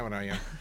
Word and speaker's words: Breuer: Se escribe Breuer, se pronuncia Breuer.